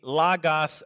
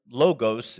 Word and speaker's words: Logos: That Jesus Christ is the Logos of God. And that's how you pronounce that word, logos, in English, but it's pronounced Logos in Greek.